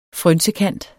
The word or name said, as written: frynsekant